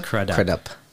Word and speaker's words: Crudup: Crudup, which is we learned how to say his name. – Crudup.